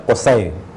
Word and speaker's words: قصی: عموماً حضرت قصے علیہ السلام کو لوگ قصی کہہ دیتے ہیں، یہ تلفظ غلط ہے، ان کا صحیح تلفظ ہے قصے۔